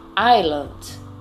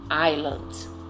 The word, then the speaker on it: island: Island, ilha. Detalhe interessante na pronúncia: o S não é pronunciado. Island.